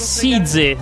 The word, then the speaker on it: SZA: Come si legge allora? – Aspetta, no, devo Size, Size.